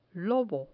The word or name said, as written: lobo